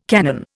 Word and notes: canon: Indian

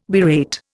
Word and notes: berate: Indian

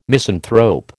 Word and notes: misanthrope: American English

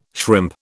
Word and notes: shrimp: British English

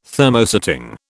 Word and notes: Thermosetting: British English

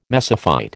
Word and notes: mesophyte: American English

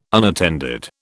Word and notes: unattended: British English

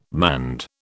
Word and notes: manned: British English

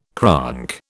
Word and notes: cronk: British English

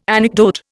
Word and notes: anecdote: Indian

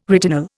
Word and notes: retinol: Indian

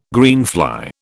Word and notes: greenfly: British English

